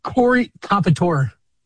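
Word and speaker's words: Corey Comperatore: Corey Compitore.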